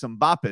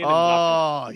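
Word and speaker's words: Mbappé: And there's Mbappe. Is that how you say his name? – Yeah, Kylian. – Mbappe.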